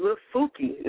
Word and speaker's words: Lufuki: And Alfa, give me the 411 on Lufuki. Now, correct me if I'm not saying it right. – No, you're saying it right. It's a Kikongo word.